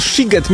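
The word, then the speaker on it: Sziget: Czy możemy ustalić, czy ten siget, mimo że jest przez SZ, to jednak jest sigetem czy sigetem? – Oczywiście, ponieważ w języku węgierskim szy czyta się jak sy, a sy czyta się jak szy.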